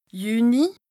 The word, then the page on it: Jüni: Bas-Rhin; Strasbourg